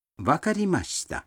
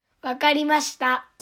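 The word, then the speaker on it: Вакаримашита (わかりました): Вакаримашита означает «хорошо», «понятно». Произнесите эту фразу, когда вы поняли, что сказал собеседник. – Послушайте, как разные люди произносят ее. – Вакаримашита.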